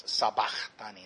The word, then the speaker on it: sabachthani: I have sabachthani, that's how it's said.